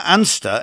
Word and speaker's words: Anster: Now, Anster, A-N-S-T-E-R, is a nickname for the nearby fishing village of Anstruther, or Ainster, or in this case, Anster. Quite confusing, I know. Anstruther is spelled A-N-S-T-R-U-T-H-E-R.